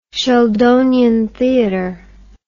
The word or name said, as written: sheldonian theatre